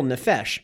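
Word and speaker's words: Nephesh (נפש): And it is then stated that Adam became a living, and I'm going to say this right, nephesh. Is that correct? – Nephesh, that's the Hebrew word.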